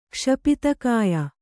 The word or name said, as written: ಕ್ಷಪಿತಕಾಯ